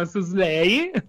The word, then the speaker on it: Szalay: L'autore è David Slay, che si scrive S-Z-A-L-A-Y, come Sza, si chiama Slay. – Oh, aspetta, S-Z-A? – L-A-Y. – L-A-Y.